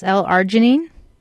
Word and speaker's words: L-arginine: I was wondering about this supplement called L-Arginine. I'm not sure if I pronounced it correctly. Mm-hmm. A-R-G-I-N-I-N-E. – Yes, L-Arginine.